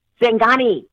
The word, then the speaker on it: Singani: And then Bolivia, they had a drink, Santingi. I'm sure I'm mispronouncing it. – I got it. It's a Singani? – Singani. – Yes.